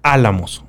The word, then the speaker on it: Álamos: Álamos con acento en la primera A. Así es. Álamos, Sonora.